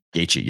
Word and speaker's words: Gaethje: I've seen this too many times, but Gaethje, how do you say Gaethje? – Gaethje, yep, Justin Gaethje.